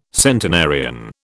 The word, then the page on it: centenarian: British English